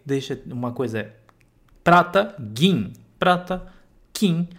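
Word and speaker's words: gin (銀): Então, só toma bastante cuidado na hora de pronunciar e de escrever, principalmente se for em hiragana, ok? Então, gin é prata, kin é ouro. Se for escrever em hiragana, gin é o ki com dakuten,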